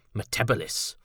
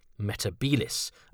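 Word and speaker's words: Metebelis: transmogrified it into Metebalis, rather than Metabilis, as John Pertwee pronounced it.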